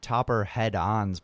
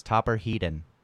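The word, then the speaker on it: Topper Headon: It is not Topper Head-On's birthday, because I don't remember who told me his name is pronounced like that. It's very much not. It's Topper Heaton.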